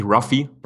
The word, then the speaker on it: Luffy: Man spricht ihn in Deutschland eigentlich Ruffy. – Aber Ruffy, okay. – Ja, genau, weil der Japaner ja das L eigentlich als R spricht.